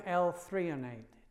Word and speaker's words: L-threonate: Now, you know, there is a type of magnesium that is particularly good for cognition, and it's called magnesium L-threonate. I'll be checking the spelling on that later. You spell it T-H-R-E-O-N-A-T-E.